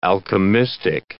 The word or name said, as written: alchemistic